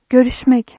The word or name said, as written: görüş-